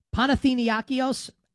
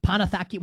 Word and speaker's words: Panathinaikos: Panathinaikos. I know how to pronounce this. I promise. I'm flubbing it at the moment. Panathakios, whatever it is.